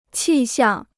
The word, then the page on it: 气象: qì xiàng